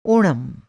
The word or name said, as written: ओणम